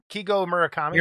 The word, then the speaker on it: Murakami: Kigo Murakami. – Murakami? – Yep. He's actually, because one of my favorite artists is Takashi Murakami, so I know how to say the last name.